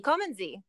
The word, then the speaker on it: Kommen Sie: Kommen Sie.